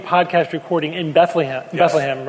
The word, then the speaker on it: Bethlehem: No, wait, you got to say it right, Steve. It's Bethlehem. – Bethlehem? – Bethlehem. Bethlehem. Yeah, not Bethlehem or Bethlehem, but it's Bethlehem. And there's a big debate. There's a big debate about what the pronunciation is and the correct pronunciation.